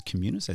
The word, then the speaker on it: Communis: It's Communis, as in community.